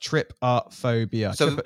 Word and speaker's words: trypophobia: It's called trypophobia. It's actually pronounced trypophobia.